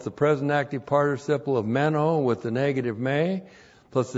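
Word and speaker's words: Meno (μένω): Meno. M-E-N long O. Meno.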